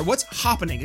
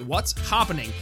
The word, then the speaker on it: What's Hopping: What's Happening, or What's Hopping, excuse me, I get it pronounced right, What's Hopping.